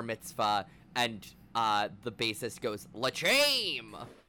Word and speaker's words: L'chaim: How do you say that word? – L'chaim. – L'chaim.